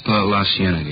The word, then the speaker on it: La Cienega: Over on, that street out that way, La Cienega. Is that how you say it? – La Cienega, yeah.